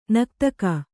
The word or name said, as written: ನಕ್ತಕ